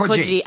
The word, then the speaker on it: Coogee: So Coogee, they call it Coogee, and right.